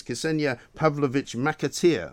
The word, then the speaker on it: Ksenija Pavlovic McAteer: It's Ksenia Pavlovich McAteer.